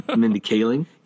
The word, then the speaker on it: Mindy Kaling: Mindy Kaling? – Thank you.